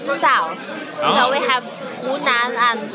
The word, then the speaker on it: Hunan: Is that how you pronounce it? – Yes, Hunan.